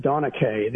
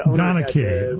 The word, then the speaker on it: Donnachadh: Donna Chatta, did I say that right? And you're getting Chardonnay out of there? – Yeah, I think it's pronounced Donna Kay. – Donna Kay. – He said D-O-N-N-A-C-H-A-D-H, Donna Kay. – He said, imagine Sean Connery saying it because it's Celtic in origin.